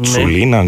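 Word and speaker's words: Čulina: Λοιπόν, στο Πάφος ΑΕΛ είναι ο Αντέ Κουλίνα. Ναι. Τσουλίνα, να δείξω πώς προφέρεται. – Τσουλίνα. – Τσουλίνα. – Ναι. Αντέ Τσουλίνα, ναι.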